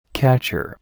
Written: catcher

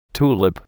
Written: tulip

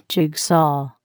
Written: jigsaw

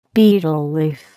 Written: betel leaf

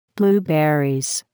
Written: blueberries

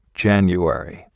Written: January

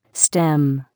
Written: stem

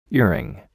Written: earring

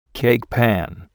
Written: cake pan

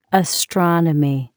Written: astronomy

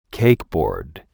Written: cake board

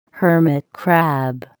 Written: hermit crab

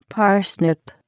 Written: parsnip

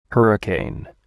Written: hurricane

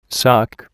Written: sock

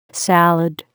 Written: salad

